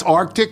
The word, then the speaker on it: RTIC: You're the Arctic cooler guy? – Oh, that's Arctic? Oh. That's how it's said.